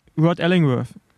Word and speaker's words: Rod Ellingworth: Rod Ellingworth. Habe ich ihn richtig ausgesprochen? Wahrscheinlich nicht. – Doch, glaub schon. Wir springen thematisch, aber die Überleitungen passen.